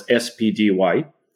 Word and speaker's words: SPDY: It's pronounced as SPDY.